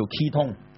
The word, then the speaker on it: ketone: 好了，另外一個最後想介紹一下的一個族呢，就是又是這個右字旁，但右手邊就是一個同同字，同等的同，大同世界的同，它的讀音當然都是同，英文叫 ketone， ketone 它是 K-E-Y 然後 T-O-N-E、